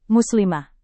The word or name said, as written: muslimah